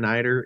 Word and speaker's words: eider: Now, your research is the population genomics of an Arctic seabird, the majestic common eider. Is that how you say it? As an eider sea duck? – Yeah, exactly. Yeah.